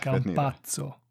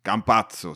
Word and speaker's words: Campazzo: Ja, Campazzo, jag vet fan hur man uttalar det. – Campazzo. – Campazzo till och med, okej.